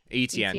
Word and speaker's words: Etienne: The Travis Etienne.